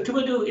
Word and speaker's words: Tuvalu: Is Tuvalu the right pronunciation? – Yes.